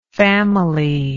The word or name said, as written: familyish